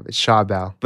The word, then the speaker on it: Schawbel: Did I say it right? – You nailed it. It's good enough. It's Schawbel.